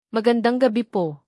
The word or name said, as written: magandang gabi